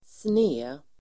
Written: sned

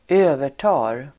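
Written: övertar

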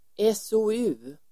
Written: SOU

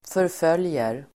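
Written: förföljer

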